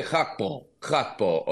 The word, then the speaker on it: Gakpo: From a Dutch perspective, and I learned the pronunciation, I've been saying Cody Gakpo. We had our Dutch colleague I mentioned. It's Cody Gakpo, okay?